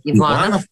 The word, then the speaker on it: Иванов: Это, конечно, художник Александр Иванов. Его иногда называют Ивановым, и это неправильно.